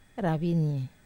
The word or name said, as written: ravinie